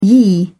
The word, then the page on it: ye: English; British English